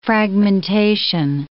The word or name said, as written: fragmentation